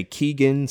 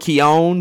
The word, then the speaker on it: Keoghan: on, it's like the Irish pronunciation is Keoghan, but I think in English, like some people say Keegan, some people say Kogan.